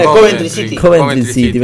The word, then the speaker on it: Coventry City: El Hull City le ganó 1-0 al Covent. Opa, me están costando la. – Coventry City.